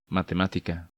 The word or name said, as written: Matematica